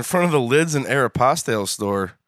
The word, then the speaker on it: Aeropostale: Yeah, I think it's Aeropostale. – Aeropostale. – Mm-hmm. Airmail. – Must be Italian. – Yeah, airmail. – Postal. – It is. Aeropostale. It's airmail, man.